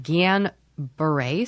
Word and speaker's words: Guillain-Barré: Guillain-Barré syndrome.